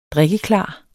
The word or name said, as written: drikkeklar